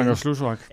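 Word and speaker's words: Kangerlussuaq: den er svær at udtale. Prøv lige at sige det igen. Kangerslusvak. – Ja, netop. Den lufthavn der, den udpeger du som, som formentlig særlig interessant for amerikanerne.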